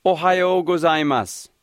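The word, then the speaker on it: Ohayo gozaimasu (おはようございます): The first part actually rhymes with the name of the Midwestern state, Ohayo. After that, there's a part that sounds like go. So Ohayo go will give you a head start on this one.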